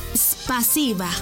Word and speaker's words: Спасибо: En ruso, gracias se dice Спасибо. ¿Cómo? Спасибо. Una vez más. Спасибо. Y se escribe Спасибо. S-P-A-S-I-B-A.